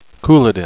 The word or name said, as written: couladin